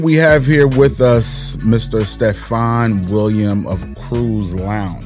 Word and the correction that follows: Crew Lounge: Is that to pronounce the proper way of the business? Crew Lounge? – Crew Lounge, correct.